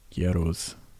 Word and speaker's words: Gueros: How do you say that? Gueros t-shirt? Gueros. G-U-E-R-O-S t-shirt, which is a margarita taco place in Austin.